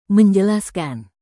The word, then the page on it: menjelaskan: indonesian